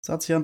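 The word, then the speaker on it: Zacian: Ähm, wie spricht man die nächsten beiden Pokémon aus? – Ich denke mal Satian. irgendwie sowas in die Richtung. – Mhm. – Und Samazenta. Satian das Schwert-Pokémon und Samazenta das legendäre Schild-Pokémon.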